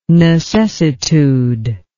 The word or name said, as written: necessitude